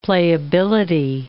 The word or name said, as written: playability